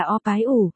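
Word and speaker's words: O-bái-u (二百五): E-bai-u, đọc là O-bái-u, nghĩa 250 nếu dịch nghĩa đen.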